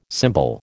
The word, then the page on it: symbol: American English